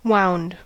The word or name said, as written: wound